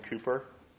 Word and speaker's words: Cowper: At the end of this series on friendship, I'm going to take a class just to look at the friendship of William Cooper and John Newton. I know I've mentioned them a bunch of times. Cooper, it's spelled Cowper if you go to look him up.